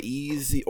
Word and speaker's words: Ys: Oh, I'm also playing Easy Origins. That's YS, for those who don't know how it's pronounced.